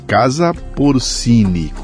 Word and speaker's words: Porcini: Visite o Instagram, @ Casa Portini. Se escreve Casa Porcini, com C.